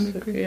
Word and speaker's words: Mimicry: Die zweite Stat Strategie ist ähm, Female Mimic Mimicry, spricht man das so englisch aus? Mimicry, ja. ja.